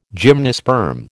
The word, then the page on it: gymnosperm: American English